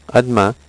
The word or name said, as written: a demain